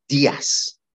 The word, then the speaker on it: Dias: Dias. Let's give him his proper pronunciation. Dias.